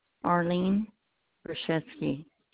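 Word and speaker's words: Arlene Grushewsky: Arlene Grushewski.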